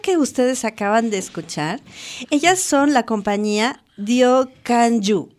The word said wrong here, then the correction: Diokaju: Diokaju. – Diokaju. Ay, gracias por decirme cómo se dice.